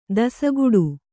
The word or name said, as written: ದಾಸಗೌಡ